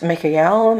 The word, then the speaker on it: Michael: And it's Michael, Michael, Michael, which is a pronunciation of Archangel Michael's name. There's many different pronunciations in different languages.